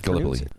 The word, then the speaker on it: Gallipoli: Gallipoli.